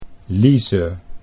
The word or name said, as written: Lize